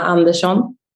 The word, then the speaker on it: Andersson: how do you pronounce your name? – For me, if I try to say my name to someone, I say Jonna Andersson, or more in Swedish, it's Jonna Andersson.